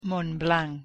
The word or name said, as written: Montblanc